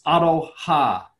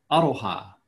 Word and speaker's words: aroha: It's aroha, aroha. And if I break that those into two syllables, it's aro and ha.